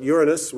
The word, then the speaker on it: Uranus: So they came up with this new name, and we now call it Uranus, which is the correct pronunciation, even though when you say it, young boys will giggle.